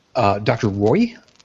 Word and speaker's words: Roi: Now, Dallin, you had also pulled up some information about the doctor, Dr. Roy. It's spelled R-O-I, so I'm having a hard time because I'm thinking to myself, is Pastor Roy? No, it's Dr. Roy, and I'm yeah, and Cash, right.